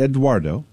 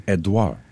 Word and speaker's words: Edouard: It is Hurricane Edouard, spelled E-D-O-U-A-R-D, not Eduardo. – Mm-hmm. – It is pronounced Edouard, French, Edouard.